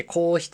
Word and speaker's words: 硬筆: そうじゃなくて、私は筆じゃなくて、こう、鉛筆で書くことの教室だから筆じゃなくて、硬い筆って書いて硬筆って読むんだけど、硬筆の教室に行ってたの。